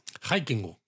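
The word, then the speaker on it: Haikingu (ハイキング): Exacto, ese neologismo, que es que en Japón son muy aficionados a crear palabras nuevas utilizando la palabra en inglés, pero pronunciada al estilo japonés. – Exacto, entonces de hiking, pues lo hacemos pronunciado al japonés, haikingu.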